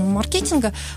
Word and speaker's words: маркетинг: А тем не менее, ведь западная трактовка все-таки ударение на первое. – Совершенно верно. В нулевых годах, когда люди начали, специалисты, активно интересоваться вопросами маркетинга, все начали читать книги, поехали за рубеж, начали изучать, что это такое, и, соответственно, начали говорить на английский манер маркетинг.